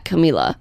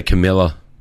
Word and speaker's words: Camila: You say her name differently than I do. – Because she says Camila. – I say Camila. – But she says Camila. – Yeah, well, she doesn't know how to say her name. – It's her name. Camila Cabello.